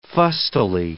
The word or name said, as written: fustily